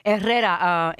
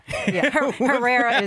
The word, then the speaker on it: Herrera: Herrera, H-E-R-R-E-R-A. – Very good. – I appreciate you trying to pronounce that in my native tongue. – Yeah, I'm not going to even try. – Herrera is fine. – You'll have me calling you Geraldo. – No. Herrera.